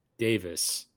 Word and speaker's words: Davies: it's spelled Davies. It's pronounced Davis.